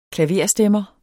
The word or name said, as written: klaverstemmer